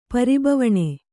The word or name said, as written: ಪರಿಬವಣೆ